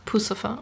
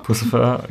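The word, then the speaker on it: Puscifer: Genau, zum Beispiel äh, Maynard James Keenan, der auch ähm, bei Perfect Circle oder Pussifer spricht man das aus? – Also ich sag Pussifer. – Pussifer, genau.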